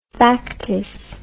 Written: factice

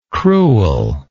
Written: creweler

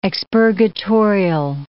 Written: expurgatorial

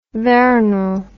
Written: veronal

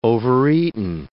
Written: overeaten

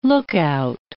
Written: lookout

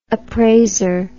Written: appraiser

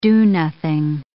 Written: do-nothing

